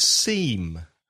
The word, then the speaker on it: seam: Another word which is unusual is seam. S-E-A-M. The same pronunciation as parece, S-E-E-M, seam. But S-E-A-M is costura.